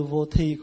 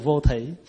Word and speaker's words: vô thỉ: Đệ tử chúng con từ vô thi cũng được. Thay vì đệ tử chúng con từ vô thỉ.